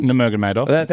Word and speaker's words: Nurmagomedov: Speaking of contact sports, Khabib Nurmagomedov, did I say that right? – Nurmagomedov. – Thank you. Very good.